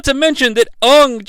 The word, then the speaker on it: Aang: I guess in Avatar, The Last Airbender, his name is Aang. Like, A-A-N-G is how you spell it. But everybody in this movie, or most people in this movie, seem to say Ung, like U-N-G.